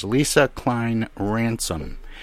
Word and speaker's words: Lesa Cline-Ransome: Uh, she is Lisa Klein Ransom.